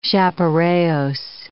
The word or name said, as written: chaparajos